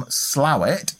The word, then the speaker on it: Slaithwaite: Emily hails from Slawit in West Yorkshire. That's spelt S-L-A-I-T-H-W-A-I-T-E. So it looks like Slaithwaite.